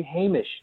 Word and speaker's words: Hamish: The way you pronounce the name Hamish. – Yeah. – Yeah. – You say Hamish. You say Hamish all the time, and I'm just going, no, guys, please. – Well, yeah, we don't encounter that name very often. So we do what we can. If we were to say his name again, yes. – Believe me, we've been corrected often enough by now. – Good. – That we would say Hamish.